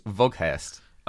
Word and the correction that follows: Weghorst: It'd be Fout, I think. – Fout Weghorst.